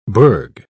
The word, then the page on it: bourg: British English